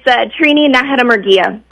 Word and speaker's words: Trini Najeri Murguia: Yeah, it's Trini Najeri Murguia.